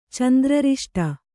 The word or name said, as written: ಚಂದ್ರರಿಷ್ಟ